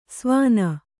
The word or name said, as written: ಸ್ವಾನ